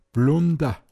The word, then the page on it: Patschai: Pinzgauer Mundart